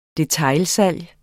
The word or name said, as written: DETAILSALG